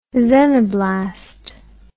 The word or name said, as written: xenoblast